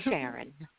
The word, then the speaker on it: Sharon: My name is Sharon. – Karen. All right. – Can you spell it for me? – S-H-A-R-O-N.